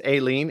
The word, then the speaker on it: Ailin: So my last name is Aileen. It doesn't sound anything like it's spelled.